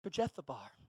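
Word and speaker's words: Kiriath-arba: I said it right this time. Kirjathbar.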